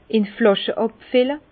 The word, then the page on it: in Flàsche àbfille: Bas-Rhin; Herrlisheim